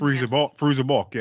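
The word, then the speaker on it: Fairuza Balk: because I'm always gonna butcher her name, Faruzka Balk, who played Faruza Balk, yeah.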